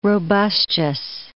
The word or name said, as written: robustious